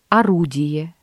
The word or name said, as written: орудие